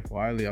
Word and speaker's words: Wylie: Wylie, I like the name. – Appreciate it. – Yeah, I've never heard that name, Wylie, actually. – Yeah. – It was good. – It rhymes with Miley.